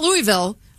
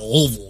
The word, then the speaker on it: Louisville: Not Louisville. – You got to say it like, yeah, peanut butter stuck in the roof of your mouth. Louisville.